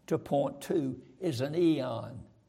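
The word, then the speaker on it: eon (αἰών): There is a little word, A-I-O-N, eon. We pronounce it eon.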